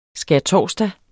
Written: skærtorsdag

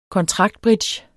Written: kontraktbridge

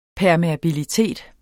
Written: permeabilitet

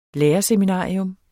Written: lærerseminarium

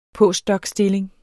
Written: postdocstilling

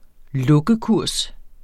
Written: lukkekurs

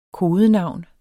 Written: kodenavn

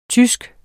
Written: tysk

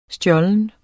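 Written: STJÅLENT

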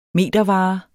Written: metervare

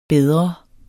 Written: bedre

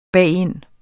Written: bagind

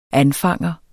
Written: anfanger

